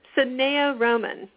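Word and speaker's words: Sanaya Roman: And I love Sanaya Rahman stuff. I think she doesn't pronounce her name like that. It's so funny. Sanaya Rahman, I think, is how she pronounces it.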